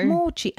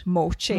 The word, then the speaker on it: mochi: Mochi. And that is how you pronounce it. – Mochi. – Mochi. – Yeah, and the brand that you get in Aldi is W-A-O.